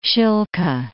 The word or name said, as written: Shilka